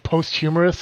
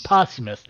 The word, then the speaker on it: posthumous: Did that pronounce that right? – Oh, yeah. – Posthumous? – I thought it was like post-humorous, something like that ain't fucking right. Like, that's completely the wrong word. Posthumous.